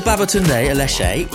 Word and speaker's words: Babatunde Aleshe: Well, Babatunde Aleshe, I pronounced that right, yeah? – Yeah, perfect.